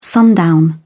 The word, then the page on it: sundown: British English